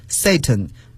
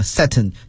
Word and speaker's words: Satan: Satan，英国人呀把它读作 Satan，美国人呢有时把它读作 Satan，就是一种语言变体了。其实 Satan 都是对的。